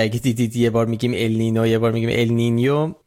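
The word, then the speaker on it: El Niño: ولی تلفظ درست اگه میخواین ال نینیو، ولی همه جا تو فارسی به چرخید کسی چیکی نمیشه ال نینیو، همه جا نوشتن ال نینو.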